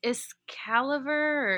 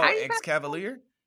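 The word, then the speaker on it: Escavalier: Um, is it Excalibur or Excavaler? – oh, Excavalier? – Excavalier? Okay, yeah, yeah, yeah, yeah.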